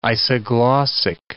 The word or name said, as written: isoglossic